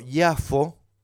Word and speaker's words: Yafo: Tel Aviv, y fuimos a, como se pronuncia en hebreo, Yafo, que para nosotros que conocemos la escritura es Jope.